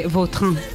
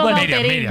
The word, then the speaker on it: Vautrin: Pues, es que si me pregunta exactamente cómo se pronuncia, pues así, claro, pero sí. Yo ya soy Vautrin. – Ya. – Está españolizado total.